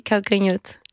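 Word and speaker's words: Calcanhotto: Calcanoto. – Oui, Calcanoto.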